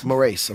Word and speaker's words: Moraes: Moraes.